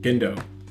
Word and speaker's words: Gendo: Genjo? Like, Genji? Genjo? Why am I stuck at pronouncing just Asian names anyway, but Gendo. Gendo.